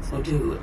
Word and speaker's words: vodou: They're part of voodoo. – It's pronounced vodou, and yes, you just drew the protective veve of the goddess Aizan.